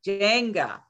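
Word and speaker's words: Njenga: Jenga. Okay, so the N is silent.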